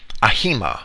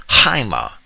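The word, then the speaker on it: Haima (αἷμα): ahima, or Haima, as it's usually pronounced, Ahima in the Strong's pronunciation guide in your concordance, which is generally what I follow.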